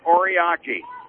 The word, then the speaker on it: Oriaki: Chandler Oriaki. Okay? Chandler Oriaki. And I have a phonetic pronunciation guide, so that is right.